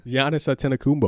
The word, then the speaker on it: Giannis Antetokounmpo: We got number one, Giannis Antetokounmpo. – That's not how you say that. – I know. – Do it again. – Giannis Antetokounmpo.